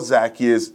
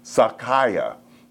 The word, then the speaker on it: Zacchaeus: Now, my Jewish friend would tell us that the correct pronunciation for Zacchaeus is Zacchaia. But because of our own English terminology, we're going to stick with Zacchaeus today.